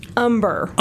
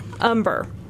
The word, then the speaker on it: Humber: For some reason, I want to say Humner, but I know it's Humner. Is that right? – Actually, it's Humber.